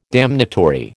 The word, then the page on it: damnatory: American English